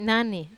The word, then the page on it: nani: Wamesa